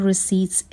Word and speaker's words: receipts: کش به معنای پول نقد و کلمه رسید هم به معنای رسید هست که پی تلفظ نمیشه. The machine automatically locked the cash and receipts inside after each transaction.